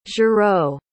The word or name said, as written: giraud